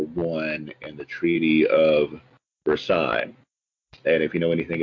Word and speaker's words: Versailles: And we have a town that's named after the palace at Versailles, but it's pronounced Versailles in Kentucky.